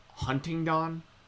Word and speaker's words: Huntingdon: It's weird because, I live on a street called Huntingdon, but, it sounds, Huntington is how, you would probably, most people would pronounce it, but it's spelled. – Oh, Huntington instead of Huntington. It's Huntingdon, D-O-N at the end.